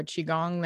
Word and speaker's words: Qigong: And I either do a Qigong that Paulette has taught me.